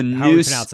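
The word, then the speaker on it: Neuse: So it's the Noose News. Yeah, I know. Noose, it's N-E-U-S-E.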